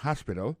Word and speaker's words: hospital: L'hôpital, c'est comme ça vous dit ça en anglais, hospital. Alors, accent tombe sur premier syllabe, il y a trois syllabes, puis she likes to visit hospital. Ensuite, elle a écrit H-O-S-P-I-T-A-L, hospital, l'hôpital.